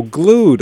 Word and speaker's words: glued: So glued. Okay, I was mispronouncing it. I was calling Glee Wed, but it's glued. – Glee Wed. – Like you're stuck like glue.